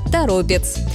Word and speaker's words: Торопец: Поэтому ударение сохраняется в корне слова – Торопец. Интересно, что в названии городов, которые заканчиваются на «ец», часто действует другое правило. Ударение тянется ближе к концу слова. Елец, Череповец, Гороховец. Но Торопец – исключение, и именно поэтому он регулярно становится ловушкой для произношения. Такие примеры в языке встречаются часто. Название места как будто само подсказывает, как его правильно произносить. Нужно только увидеть его происхождение. И в этом случае все однозначно. Правильно говорить «Торопец».